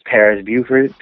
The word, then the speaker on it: Paris Buford: Well, my Facebook is my government name, which is Paris Buford, PARISBUFORD.